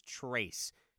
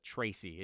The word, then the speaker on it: Trayce: I believe it's Trace Jackson Davis, not Tracy. It's spelled T-R-A-Y-C-E.